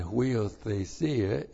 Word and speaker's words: huiothesia: P-N-E-U-M-A, and that's the word spirit, and then huiothesia, H-U-I-O-T-H-E-S-I-A, huiothesia. So, pneuma huiothesia, this time, pneuma refers to God the Holy Spirit.